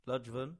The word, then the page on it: Ludgvan: English